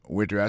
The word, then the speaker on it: waiter: Waiter ça veut dire accent tombé sous premier syllabe là waiter, W A I T E R.